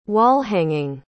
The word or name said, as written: wall-hanging